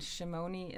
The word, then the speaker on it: Shimoni: Okay, Orit is spelled O-R-I-T, and Shimoni is spelled, I have the worst name for a website, S-H-I-M-O-N-I.